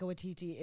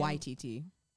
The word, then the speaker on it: Waititi: The emphasis is on Y. – Taika Waititi. – No. – Taika Waititi. – Yes. – Taika Waititi. Taika Waititi. – Yes. No, I used to say it that way as well. Taika Waititi. But that's how he says his name.